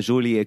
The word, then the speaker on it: Jolie: Die meisten sprechen sie falsch aus, weil Jolie kommt ja, ist ja wie Folie.